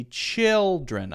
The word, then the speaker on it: children: So if I see children, I remember, okay, chill is the stressed syllable, not dren.